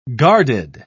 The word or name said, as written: guarded